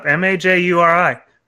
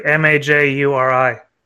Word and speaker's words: Maggiore: Maggiuri. Frank. – I would. – That's my great uncle. – Okay, can you spell it again? – Frank, FRANK, Maggiore, Maggiuri. – Maggiuri.